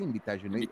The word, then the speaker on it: Invitational: Invitation. ¿Cómo se dice? Invitational. – Invitational. – Invitational. Muy bien, gracias, gracias.